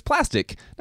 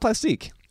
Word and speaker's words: plastic: But it's plastic, not Plastique.